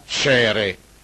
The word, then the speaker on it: Caere: Caire o Caere si pronuncia Cere.